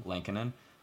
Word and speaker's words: Lankinen: I don't know how to pronounce it. – Lankinen.